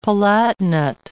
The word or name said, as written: palatinate